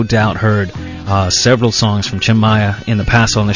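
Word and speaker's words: Maia: If he was from the States, he'd be Tim Maya. But Brazilian pronunciation of his name, Tim Maya.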